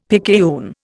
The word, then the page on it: picayune: Indian